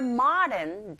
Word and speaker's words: modern: Now try the second listening point, which is more modern than it looks. 모던이라고 발음하기보단 그렇게 들린다라기보다는 오의 강세가 갑니다. 그럴 때 아에 가깝게.